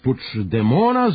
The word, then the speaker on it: Puigdemont: Svaki navijač sa tri razreda osnovne zna da se bivši katalonski fudbaler Puyol zove Carles, ali zato puć, puć, puć, puć demona zovemo i Carlos i Carles. – Katalonski lider zove se Carles Puć demon, što je najpribližnije pravilima katalonskog izgovora, a u jezičko raspetljavanje krećemo od imena.